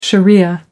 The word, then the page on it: sharia: American English